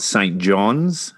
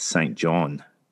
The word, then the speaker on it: Saint John: It's not Saint John's, as I was corrected when I was in New Brunswick, which is the uh, capital of Newfoundland. It's Saint John.